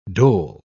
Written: dhole

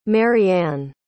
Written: marianne

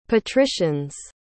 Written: patricians

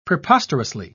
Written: preposterously

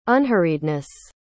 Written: unhurriedness

